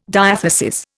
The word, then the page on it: diathesis: Indian